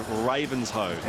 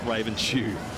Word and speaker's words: Ravenshoe: fans who corrects me on my pronunciation of the place in far north Queensland, Ravenshoe, not Ravenshoe. Ravens don't wear shoes, so I can imagine that would be a...